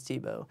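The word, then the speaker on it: Tiebout: No, I'm not talking about the previous football player, but Charles Tebow. – Spelled differently. – Spelled a little differently, but pronounced the same.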